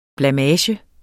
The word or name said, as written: blamage